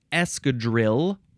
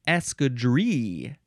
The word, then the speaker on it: escadrille: It's escadrille or escadrille. It is spelled E-S-C-A-D-R-I-L-L-E. Seems like escadrille would be the more proper way to say it, because it is French, but you know, we Americans, we don't like to say things proper. We like to say escadrille.